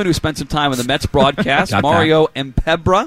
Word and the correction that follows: Mpemba: Am I saying that right, Ken Laird? I hope I am. Formerly the Detroit Tigers. – He threw like an extra R in there. He threw like an R in the last name.